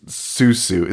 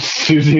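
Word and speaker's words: Siouxsie: And he's like, you think this kid's listening to fucking Susu? Is that how you say it? Susu? I don't even know how you say it. – Susie and the Banshees? – Yeah.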